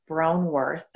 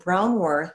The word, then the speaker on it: Braunwarth: Or you could directly just email me too, which is my name, Pam Braunwarth. And Braunwarth is spelled Braunwarth at Gmail.